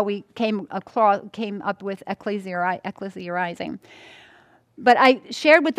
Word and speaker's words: ecclesia: It's ecclesia is the proper way to pronounce it. Ecclesia. I kept saying ecclesia. It just seems elite. But it's actually, I looked it up. I'm going, how do we pronounce this name? It's ecclesia.